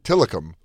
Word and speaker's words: Tilikum: Tiktum. How do you say that name? – Tilikum.